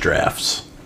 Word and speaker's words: draughts: I'm not from England, so it's possible it's also pronounced drafts, but it's spelled A-U-G-H-T-S.